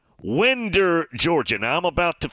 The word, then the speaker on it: Winder: I could only laugh when thinking of the show Breaking Winders and Jeff in the Borough. Yeah, Break at Winder. Yeah, that city is pronounced Winder, Georgia. But yes, that is kind of funny. Shannon. W-I-N-D-E-R.